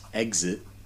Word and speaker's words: XIT: I think it's pronounced Exit, X-I-T, from Albuquerque.